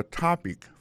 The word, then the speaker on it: topic: What's your topic for today? Alors, mot topic là, qui signifie sujet, ça veut dire accent tonique là tombé sous premier syllabe là.